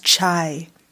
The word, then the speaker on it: chai: Yeah, we pronounce it cha because that's just how you pronounce it. But chai is like the term that everybody knows, so I just put it as chai latte. – Yeah, everyone says chai latte. It's not. It's cha. So we're drinking cha right now. So it's half past cha. That's how I'm always going to pronounce it, but it's spelled chai.